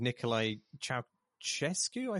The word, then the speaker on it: Nicolae Ceaușescu: including the fairly kind of niche pick of Nicolae Ceaușescu, I think is how you pronounce his name.